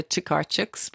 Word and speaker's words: Tokarczuk: I had to look up the name, the pronunciation of her name. – Tokarczuk.